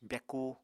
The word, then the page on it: byakú: apatani